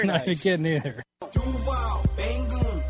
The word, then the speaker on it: Duval: Yeah, we need the long U. Duval. – Duval.